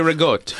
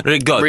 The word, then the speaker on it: ricotta: I love ricotta. – You say it like Giada. – Yeah. – No, we say rigotte. – Rigotta. – Rigotte.